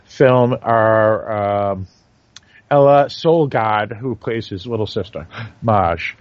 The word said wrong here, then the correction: Maj: Or May, I'm sorry, May. M-A-J, the J is pronounced as a Y in Danish.